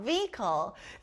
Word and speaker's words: vehicle: 여기 가운데 silent H가 들어가요. Vehicle.